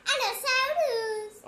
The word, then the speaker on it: Allosaurus: Se pronuncia Allosaurus.